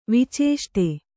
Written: ವಿಚೇಷ್ಟೆ

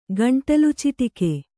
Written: ಗಂಟಲುಚಿಟಿಕೆ

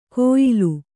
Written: ಕೋಯಿಲು

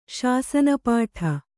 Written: ಶಾಸನಪಾಠ

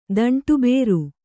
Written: ದಂಟುಬೇರು

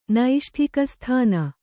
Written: ನೈಷ್ಟಿಕಸ್ಥಾನ

